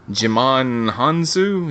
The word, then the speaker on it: Djimon Hounsou: That is Djimon Hounsou. Is that how you pronounce his name? Yeah. – Who, yeah.